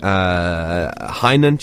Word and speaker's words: Hainan: I actually want to touch on the silly elements, because on the track you're referencing Hainan chicken, is that how you say it? – That's correct, yeah.